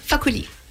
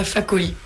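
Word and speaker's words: Fakoly: c'est Tikenja focoli, Fakoli. – oui, Tikenja Fakoli, oui.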